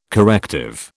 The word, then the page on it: corrective: British English